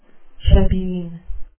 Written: shebeen

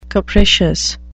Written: capricious

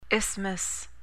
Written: isthmus

